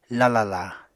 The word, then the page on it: lalala: Apatani